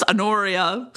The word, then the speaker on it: Honoria: I want to talk about, how do you say her name? I'm like, Honoria? Like, Honoria Prudence? Honoria. I kind of dropped the H, right? – I've always dropped the H.